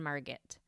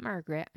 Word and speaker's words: Margit: There, they had four children, Carl, Mabel, Harold, and Margit. Not Margaret, I'm not pronouncing it wrong, it was Margit, M-A-R-G-I-T. Little Carl loved trains, and he would often stop by the rail yard on the way to visit his father at work.